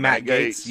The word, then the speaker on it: Gaetz: Matt Gaetz. – Okay. Here we go. – What is that? Is that his name? – Matt Gaetz.